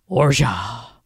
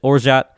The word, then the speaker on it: orgeat: I just think saying orja sounds pretentious. I split the difference and pronounce it orjat, which is the accepted English pronunciation.